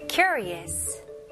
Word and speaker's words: curious: Three syllables. 네, 세 음절이죠? curious. curious. 네, 강세는 앞부분에 해주셔야 됩니다.